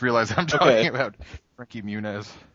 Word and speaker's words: Muniz: And he keeps saying Frankie Muniz's name wrong. It's like, I'm here with Frankie Mungongo. It's like, it's Muniz.